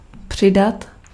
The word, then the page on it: add: /ˈæd/; [æd]